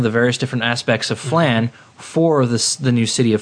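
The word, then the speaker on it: Phlan: Okay, the central plot of the game is that New Flan, P-H-L-A-N, I think it's pronounced Flan. – Flan, that's how it always pronounced.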